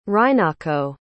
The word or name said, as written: rinako